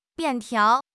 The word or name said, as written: 便条